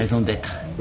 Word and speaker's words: raison d'être: How you say it? Raison d'être? – Right? Raison d'être.